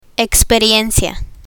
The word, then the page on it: experiencia: spanish